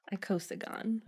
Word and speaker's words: icosagon: How am I pronouncing that? Can I get a pronunciation, if you please? – Icosagon.